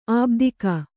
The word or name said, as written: ಆಬ್ದಿಕ